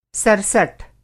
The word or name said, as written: सरसठ